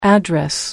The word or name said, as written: address